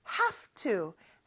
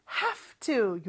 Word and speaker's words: have to: 왜 have인데 V 소리가 안 나나요? 라고 캐치한 친구들이 혹시 있나요? 자, 이 경우는요. have가 F 발음으로 변해요. 그래서 have to. have to 요렇게 발음이 됩니다.